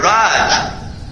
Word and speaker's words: Raj: The word Raj, long A, R-A-J.